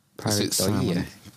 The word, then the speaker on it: Pareidolia: Do you pronounce the L? – Pareidolia.